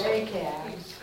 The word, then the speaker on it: Rahab: Rachab. How do you say that name? Solomon. Yeah. Booze? – Boaz. – Rahab.